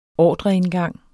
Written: ordreindgang

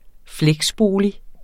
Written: fleksbolig

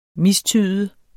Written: mistyde

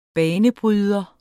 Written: banebryder

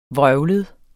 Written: vrøvlet

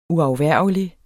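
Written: UAFVÆRGELIGT

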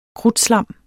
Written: krudtslam

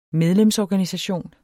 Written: medlemsorganisation

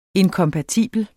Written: INKOMPATIBLE

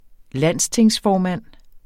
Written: landstingsformand

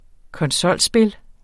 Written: konsolspil

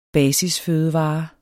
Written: basisfødevare

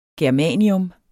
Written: germanium

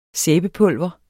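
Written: SÆBEPULVER

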